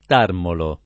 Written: tarmolo

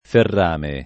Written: ferrame

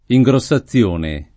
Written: ingrossazione

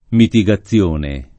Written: mitigazione

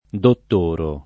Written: dottorare

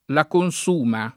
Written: Consuma, la

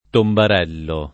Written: tombarello

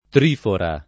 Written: trifora

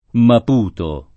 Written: Maputo

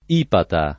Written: Ipata

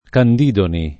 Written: Candidoni

